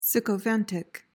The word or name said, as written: sycophantic